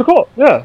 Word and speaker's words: Sukkot: Is that how you say it? How do you say it? – Sukkot, yeah. – The Sukkot. Did I get it? The Sukkot. Yeah, Sukkot. – No, Sukkot. Sukkot. Not you.